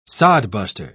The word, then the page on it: sodbuster: British English